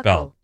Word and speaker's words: buccal: B-U-C-H-A-L? – Mm-hmm. B-U-C-C-A-L is how it's pronounced. – Oh. – But it's how it's spelled. – Buckle. Yeah. – B-U-C-C-A-L? – Anyway, buckle.